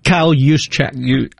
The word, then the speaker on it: Juszczyk: Kyle Juszczyk.